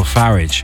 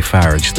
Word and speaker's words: Farage: Farage. And they're not fans at all. Look, I know his surname isn't pronounced that way, Farage, the particular British radio presenter who insists on pronouncing his name that way.